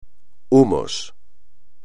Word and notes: humos: espagnol

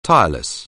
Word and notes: tireless: anglais

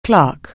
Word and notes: clerical: anglais

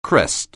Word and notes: crest: anglais

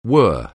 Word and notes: whirr: anglais

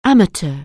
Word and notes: amateur: anglais

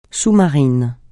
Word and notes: sous-marin: francais

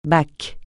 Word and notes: bac: francais

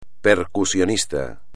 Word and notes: percusionista: espagnol

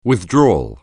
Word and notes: withdrawal: anglais

